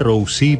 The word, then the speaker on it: Rozee: Se pronuncia en realidad Rossi, pero nadie lo pronuncia bien, así que digo, si decís Rossi o Rosé está bien, no pasa nada. – Se escribe R-O-Z-E-E.